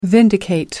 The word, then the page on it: vindicate: American English